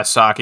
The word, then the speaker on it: Psaki: Yeah, Psaki.